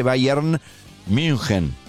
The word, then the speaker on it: Bayern München: Si es Bayern con Y, Bayern München. Si lo vas a pronunciar en alemán. O lo pronuncias en castellano o en alemán.